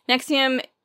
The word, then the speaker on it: NXIVM: Nexium is actually spelled N-X-I-V-M, but they pronounce it Nexium.